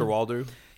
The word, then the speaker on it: Coster-Waldau: Coster-Waldau. – Yeah, Nikolai Coster-Waldau.